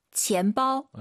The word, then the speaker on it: 钱包: 근데 조금 힘을 빼시면 어떨까요? 한번 들어볼게요. – 钱包. – 네, 지엠빠오. 약간 여러분들께서 쌍비읍이 너무 강해서 지엠빠오 이렇게 하는 것보다는 약간 선생님, 쌍비읍과 비읍의 중간은 지엠빠오 어때요?